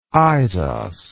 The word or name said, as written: eidos